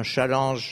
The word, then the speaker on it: challenge: Le problème, là, justement, c'était, comme vous dites, un challenge, j'aime mieux le prononcer à la française parce que ça existe en ancien français, donc c'est un mot qui est passé en anglais et qui nous revient avec la prononciation anglaise.